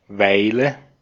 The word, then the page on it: aika: /ˈɑi.kɑ/